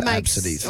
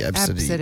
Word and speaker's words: Abcde: Sorry, it's written phonetically here as Absidy. – Well, that makes more sense of why they would do that. – A, B, C, D, E, Absidy.